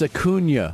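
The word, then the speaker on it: Acuña: Bryce Harper responds to the guy and is telling him, no, you're pronouncing it wrong. It's Acuna. And he's even like putting where the tilde is.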